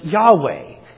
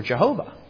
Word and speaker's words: Yahweh: It should be pronounced Yahweh. If you pronounce Yahweh with the vowels for Adonai, you come up with Yahovah or Jehovah, which is how we came up with that.